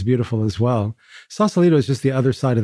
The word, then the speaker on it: Sausalito: And we're in a place now, correct me if I pronounce this incorrectly, is it Sausalito? – Mm-hmm. – Sausalito.